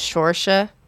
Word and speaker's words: Saoirse: She wore a sign on Ellen the other day that how to pronounce her name. I think it was Shorsha. – Yeah. – Shorsha.